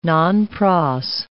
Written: nonpros

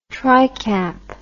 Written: tricap

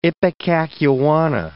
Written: ipecacuanha

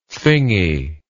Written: thingy

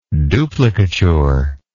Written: duplicature

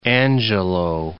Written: Angelou

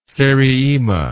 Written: seriema

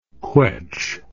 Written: quetsch

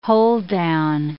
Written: hold-down

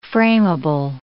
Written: frameable

